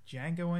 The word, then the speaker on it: Django: Well, the first one we have to mention, of course, is Django Unchained came out. Hey, the D is silent.